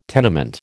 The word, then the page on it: tenement: American English